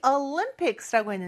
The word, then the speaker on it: Olympics: 근데 발음이 조금 달라요. Olympics. 이렇게 lim, 여기가 강세가 있으니까 꼭 그거 지켜서 발음해 주세요.